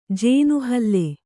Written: ಜೇನುಹಲ್ಲೆ

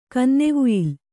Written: ಕನ್ನೆವುಯಿಲ್

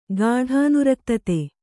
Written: ಗಾಢಾನುರಕ್ತತೆ

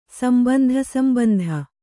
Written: ಸಂಬಂಧಸಂಬಂಧ

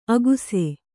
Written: ಅಗುಸೆ

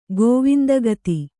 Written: ಗೋವಿಂದಗತಿ

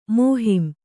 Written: ಮೋಹಿಂ

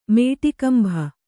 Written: ಮೇಟಿಕಂಭ

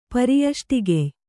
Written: ಪರ್ಯಷ್ಟಿಗೆಯ್

